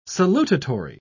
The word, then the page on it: salutatory: British English